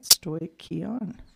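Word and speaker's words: stoicheion: And from the Strong's Concordance, okay, so it's pronounced, yes, we're doing it right, stoichion.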